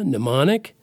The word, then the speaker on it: mnemonic: And look, they both start with they both start with the pronunciation of n, pneumonia, mnemonic, but they one starts with a p and starts the other starts with an m, and yeah, it's all so weird.